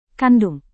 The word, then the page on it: kandung: Indonesian